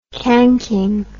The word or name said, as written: cranking